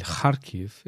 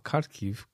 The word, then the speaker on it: Kharkiv: Et la ville s'appelle Kharkiv et pas Kharkiv, Kharkiv. Tania imite parfois la phonétique française, mais il faut prononcer les noms de des villes comme ils se prononcent.